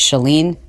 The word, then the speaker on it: Chalene: It's Shalene. It's not Shalene. It's not Charlene. It's Shalene. Like, shut the door.